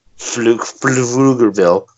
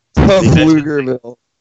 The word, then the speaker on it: Pflugerville: But they literally, in this tiny little article, they mention the town of Pflugerville, which is P-F-L-U-G-E-R. Pflugerville. – Pflugerville. – They mention it like 20 times. – Why is there a silent P on your town? Get rid of the P. What the fuck do you need the P for? – See, no, this is not right.